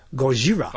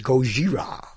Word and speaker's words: Gojira: But, oh, yes, I told the boys we're going to see Gojira. – Okay. – Gojira. That's how you say it in Japanese. That's the original name of the monster in Japanese, Gojira.